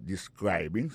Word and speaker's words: describing: il a prononcé mot de describing accent tombé sur deuxième syllabe là ça veut dire mot ça a trois syllabes describing so, I'll be talking about describing your job history mais pas parler sous description travail.